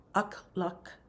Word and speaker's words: Akhlak: It's spelled A-K-H-L-A-K and then Allah, two words. Akla Allah. It's interesting, those two K's, isn't it? And then the ah, all the way through. Akla Allah.